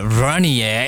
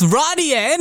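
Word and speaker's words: Runaway: Nuts runny, yeah. Why did I say it like that? Runny, yeah. It's runny, yeah, innit?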